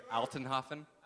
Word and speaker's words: Altenhoffen: Last name's Altenhoffen. It's German.